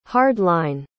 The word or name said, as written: hard-line